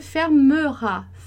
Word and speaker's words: fermera: Você tem duas consoantes depois do primeiro E. Então, você vai pronunciar esse E como E. Fermera, fermera, fermera, tá? Nada de fermera.